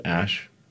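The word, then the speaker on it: Æ: So, I mean, it's just X, the letter X, and then the A-E is like pronounced Ash, and then A-12 is my contribution.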